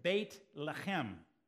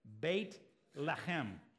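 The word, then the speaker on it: Beit Lechem (בית לחם): Now, in Hebrew, that word is pronounced this way, Beit Lechem. Say it with me. It's one of these great words. It's one of these Hebrew words to say, Beit Lechem.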